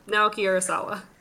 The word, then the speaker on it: Naoki Urasawa: Yep, Naoki Urasawa.